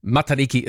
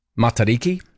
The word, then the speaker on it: Matariki: Your pronunciation of Riki in Matariki is great. But use mata instead of mata for the mata part. No macron or long vowel in mata.